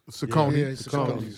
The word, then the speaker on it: Saucony: Xavier McDaniel with the Sauconies. – Saucony. Please say it correctly.